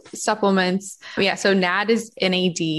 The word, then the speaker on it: NAD+: Not you, how people do, because it's written capital NAD+. Yeah. I've heard it said so many ways. I've even heard it, the way you say it is NAD, the plus is silent.